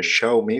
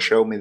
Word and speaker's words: Xiaomi: Por eso, fíjate que, la semana pasada le di mucha importancia a que el Redmi Note 7 de, Xiaomi, que es como decía Hugo Barra que había que pronunciarlo, eh, Xiaomi, como Xiaomi de Money, eh, que hubieran ya, mm, le hubieran flasheado Ubuntu Touch.